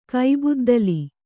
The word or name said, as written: ಕೈಬುದ್ದಲಿ